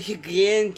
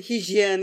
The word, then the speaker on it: hijyen: Böyle higyen gel mi dedi bir şey dedi tamam mı? – Higyen, hijyen yani bunu hakikaten anlayamıyorum.